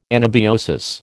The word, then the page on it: anabiosis: American English